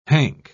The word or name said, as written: hank